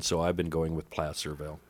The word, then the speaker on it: Placerville: I'm going to pronounce it Placerville because I think placer is the type of mining, and you would pronounce that placer mining.